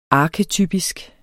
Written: arketypisk

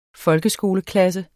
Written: folkeskoleklasse

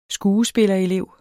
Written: skuespillerelev

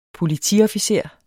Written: politiofficer

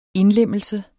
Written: INDLEMMELSE

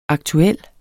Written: aktuel

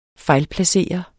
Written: fejlplacering

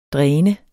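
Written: DRÆNET

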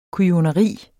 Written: kujoneri

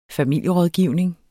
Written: familierådgivning

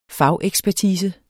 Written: fagekspertise

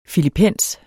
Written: FILIPENSERNES

